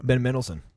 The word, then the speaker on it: Ben Mendelsohn: Ben Mendelsohn.